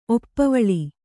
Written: ಒಪ್ಪವಳಿ

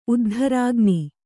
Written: ಉದ್ಧರಾಗ್ನಿ

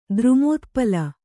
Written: ದ್ರುಮೋತ್ಪಲ

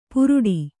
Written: ಪುರುಡಿ

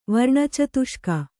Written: ವರ್ಣಚತುಷ್ಕ

